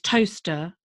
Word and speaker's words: Towcester: And then there's Toaster, which is spelled Toaster or Towcester.